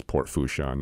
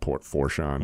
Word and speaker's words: Fourchon: I spent a lot of time here during the BP oil spill because the network realized I used to work here and I could pronounce Port Fourchon, not Port Fourchon.